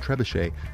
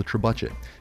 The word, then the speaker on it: trebuchet: The word looks French and seems like it should be pronounced trebuchet, but here in America, it's called a trebuchet.